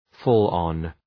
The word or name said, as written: fall on